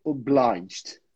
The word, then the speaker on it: obliged: This one we say obliged.